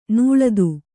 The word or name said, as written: ನುಲಿತ